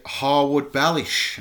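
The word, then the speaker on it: Harwood-Bellis: Right, now we move into Harwood Bellish, and I've said it right this time. – Yes, you did.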